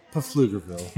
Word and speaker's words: Pflugerville: It's pronounced Pflugerville.